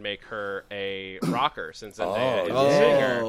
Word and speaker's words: Zendaya: The chat wants you to know that it's Zendaya. – Oh, thank Zendaya. – You. I've said it wrong for a long time. Zendaya, really.